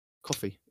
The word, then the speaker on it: Ko-fi: Coffee. It's coffee, isn't it? I always say it's with a K.